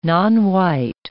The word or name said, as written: nonwhite